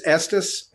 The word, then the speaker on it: Estes: Look, my last name is Estes, and so I've been called Estes, Estes, Estes, which rhymes with a part of the male anatomy.